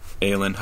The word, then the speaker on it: Aelin: I was going to go with the name Aelin. – Aelin. How do you spell that? – A-E-L-I-N. – A-E-L-I-N.